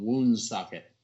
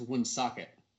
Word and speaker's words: Woonsocket: but there's a place there called Woonsocket. – I don't believe it does. – Actually, I think it's pronounced Woonsocket, but yeah.